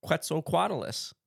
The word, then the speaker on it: Quetzalcoatlus: Quetzalcoatlus.